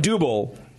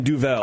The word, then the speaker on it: Duvel: Well, he's saying Dubel, and you're saying Duvel. – Oh, Duvel. – Yeah. – Duvel or Dubel? – Yeah. – Dubel. You say Duvel, I say Dubel. – Spell it. Spell it. – D-U-B-B-E-L. – Okay, that's where you got the difference.